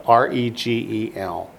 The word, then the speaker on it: regel: Spell it R E G E L, regel.